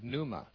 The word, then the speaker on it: pneuma: In the Greek language, it's the word pneuma, spelled with a silent P at the beginning, P-N-E-U-M-A, pneuma.